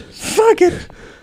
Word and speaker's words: Fugit: It's Fugit, but it's spelled Fugit. Fuggett.